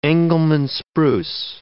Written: Engelmann spruce

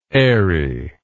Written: eyrie

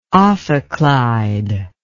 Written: ophicleide

